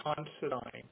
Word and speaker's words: Considine: My name is Michael Considine. It's spelled C-O-N-S-I-D-I-N-E.